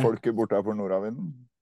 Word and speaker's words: Folket Bortafor Nordavinden: Hopefully I roughly pronounce that right. – You said it fairly well, actually. – Yeah. – Folket, Bortafor, Nordavinden. I think you said it perfect.